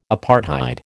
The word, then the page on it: apartheid: American English